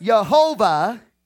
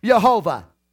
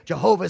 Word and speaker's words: Jehovah: But it's pronounced Jehovah. But he goes on to say, it's not known how. He says it's not pronounced. He said it's not pronounced Jehovah, and he sends you to Adonai, the word Adonai. And he says they got the vowel points from Adonai and interjected that into Yod-Heh-Vav-Heh. Strong's Concordance, or Strong's Lexicon, says that himself.